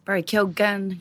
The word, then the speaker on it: Keoghan: Barry Keoghan, pronounces the G, so we'll pronounce the G too.